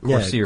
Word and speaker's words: Corsier: I hope I'm saying that right. – Corsier. Yeah, Corsier-sous-Vivet.